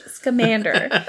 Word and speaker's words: Scamander: And he keeps calling, instead of calling Newt Scamander Mr. Scamander, he keeps calling him Mr. Scamander. – Okay. – And it bothered me so much, because I'm like, no, it's Newt Scamander, not Scamander.